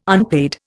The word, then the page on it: unpaid: Indian